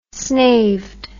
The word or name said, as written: snaved